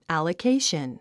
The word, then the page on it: allocation: æ`ləkéiʃən; アラケイション